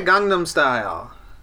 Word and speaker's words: Gangnam Style: Gangnam Style.